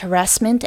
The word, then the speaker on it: harassment: The word harassment, the stress is on the second syllable. The first A is a short uh sound, and the second A is a long flat ah sound. Harassment.